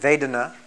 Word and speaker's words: Vedana: In Sanskrit, Vedana, V-E-D-A-N-A, long A, Vedana, feeling, that is pleasant, unpleasant, neutral.